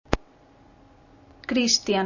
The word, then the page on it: Cristian: Spanish